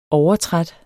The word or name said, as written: overtræt